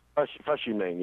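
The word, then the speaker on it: Fush Yu Mang: Well, and that first album was, is it Fush You Mang? Is that how you pronounce that? – Fush You Mang, yeah.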